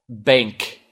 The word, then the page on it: reform: [ri'fɔ:m]